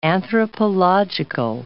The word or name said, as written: anthropological